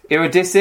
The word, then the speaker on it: Eurydice: It is Eurydice. – Eurydice. – You have to get the flow right, you know what I mean? But Eurydice. – The first time he said it, it was like you're a dice? I was like, no, you're a dice.